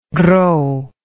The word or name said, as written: gros